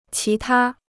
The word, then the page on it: 其他: qí tā